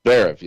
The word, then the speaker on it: Zverev: How do you say it? Zverev? – Zverev, yeah.